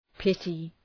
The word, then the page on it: pity: ‘pıtı